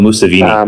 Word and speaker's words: Museveni: Oh, Museveni.